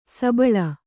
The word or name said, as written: ಸಬ್ಬಲು